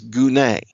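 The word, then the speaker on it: gune (γυνή): The word is gune. That's a weird word, but that's how you pronounce it.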